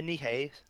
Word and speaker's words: Nihei: It was directed by Hiroyuki Sashita, written by Tsutomu Knight. Okay, I'm butchering this. I'm sorry. Anyone who this is their language. – Tsutomu Nihei, maybe. – Yeah, Nihei.